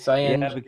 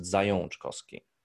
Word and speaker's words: Zajączkowski: I just see the Z-A-J-A-C-Z, and I would have gone Zajączkowski. So it's wrong. So it's Zajączkowski. – Yeah, because this, you know, this second A is our, you know, dialect A with this, you know, like with the tail under the A, which is pronounced on. And that's why you pronounce it Zajączkowski.